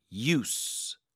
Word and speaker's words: use: 끝에는 use가 아니라 use. use. 짧고 강하게 s 발음.